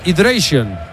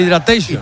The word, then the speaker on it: hydration: Es el hydration, no el hydratation.